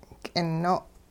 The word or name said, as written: k'ino'